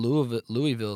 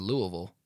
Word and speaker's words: Louisville: I was like, man, Louisville is so nice. He goes, you mean Louisville? L-E-W-A-ville is the way he pronounced it.